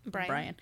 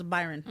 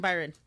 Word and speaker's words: Byron: I have to over-pronunciate his name because I just want to call him Brian. – I think because we have so many Bryants in my life, I just see B-Y. Yeah. I'm like, oh, it's Byron. Mm-hmm. I mean, Byron. – Right. Well, now you say it correctly.